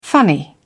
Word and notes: funny: British English

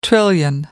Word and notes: trillion: American English

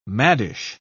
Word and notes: maddish: British English

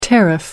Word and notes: tariff: American English

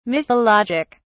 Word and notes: mythologic: British English

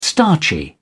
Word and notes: starchy: British English

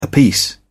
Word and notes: apiece: British English

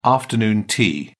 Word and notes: afternoon tea: British English

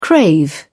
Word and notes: crave: British English

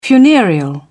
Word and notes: funereal: British English

